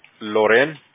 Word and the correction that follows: Laurel: Así, como se escribe así, Loren. L-O-R-E-L. – Oh, Laurel. Okay.